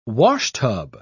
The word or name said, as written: washtub